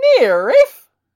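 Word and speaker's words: NIIRYF: Those are the letters for Not If I Reboot You First, and it's pronounced NIRIF!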